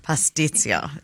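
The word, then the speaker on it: pastitsio: How do you pronounce it? – Pastizo. – What's that again? – Pastizio. Yeah. Pastizio. – Okay, so it is an eel.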